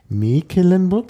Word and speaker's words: Mecklenburg: Also es war zuerst Mecklenburg, da brauchte man kein zweites E, weil das, weil das E in offener Silbe steht. yeah. Dann ist es lang.